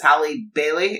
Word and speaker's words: Halle Bailey: Isn't it Holly Or Halle Bailey, that's what it is. Halle Bailey, is that how you say it? Yeah.